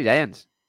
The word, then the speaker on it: Giants: Y ¿Cómo, ¿Cómo es? – Giants. – Vale, vale, vale. – ¿No? ¿No se dice así? – Sí, sí, sí, Giants.